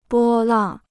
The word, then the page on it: 波浪: bō làng